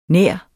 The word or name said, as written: nær